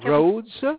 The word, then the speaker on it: Rhodes: My name is Don Rhodes, R-H-O-D-E-S is the last name. First name, Don, D-O-N.